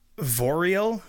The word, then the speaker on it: Viorel: Vorel, V-I-O-R-E-L.